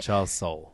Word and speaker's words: Charles Soule: Like, we've mispronounced his name so consistently that I now can't remember what's the actual correct way. – Charles Soule. – Soule, there we go.